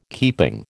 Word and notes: keeping: American English

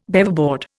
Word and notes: Beaverboard: Indian

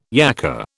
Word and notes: yacka: British English

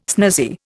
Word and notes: snazzy: Indian

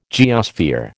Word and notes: geosphere: American English

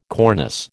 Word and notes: cornice: American English

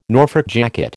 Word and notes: Norfolk jacket: American English

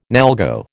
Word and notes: NALGO: American English

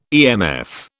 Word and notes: EMF: British English

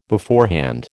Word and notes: beforehand: American English

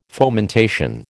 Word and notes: fomentation: American English

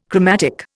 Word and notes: chromatic: Indian

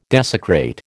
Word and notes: desecrate: American English

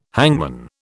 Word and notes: hangman: British English

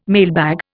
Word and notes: mailbag: Indian

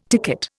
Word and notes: ticket: Indian

